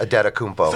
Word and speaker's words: Antetokounmpo: Kostas Antetokounmpo, which I still believe is pronounced Adetokounmpo.